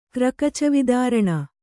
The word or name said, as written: ಕ್ರಕಚವಿದಾರಣ